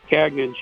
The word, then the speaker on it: Gagnon: French pronunciation would be Gagnon. – Yeah, that's what we bow to the pressures of the English-speaking world.